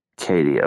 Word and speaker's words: Katie: I thought it was like Katie, like, but it's just like a cute way to spell it. – Yeah, Addie's right. My name's just Katie. – Katie, okay, got it.